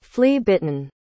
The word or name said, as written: flea-bitten